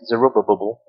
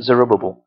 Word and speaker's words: Zerubbabel: Sometimes I get a little bit confused and I pronounce it Zerubbabel. There's too many bubbles in it. All right? But that was the first return under Zerubbabel.